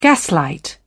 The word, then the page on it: gaslight: British English